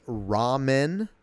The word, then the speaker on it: Rawmen: And then uh, the next game is Rawmen, and it's not spelled uh, like the noodle. It's spelled R A W M E N.